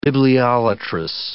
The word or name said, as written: bibliolatrous